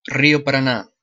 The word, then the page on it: Río Paraná: [ˈri.o paɾaˈna]; Spanish; pe